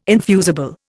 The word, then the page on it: infusible: Indian